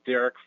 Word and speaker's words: Derek: Hi, my name is Derek Furr. That's spelled D-E-R-E-K, last name F-U-R-R.